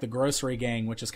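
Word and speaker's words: Grossery Gang: Grocery Gang, but it's spelled G-R-O-S-S, like gross... So, Grocery Gang.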